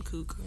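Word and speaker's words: Cooke: It could be pronounced Cook, but it's C-O-O-K-E. So in my brain, it's Cook. – It's Cook.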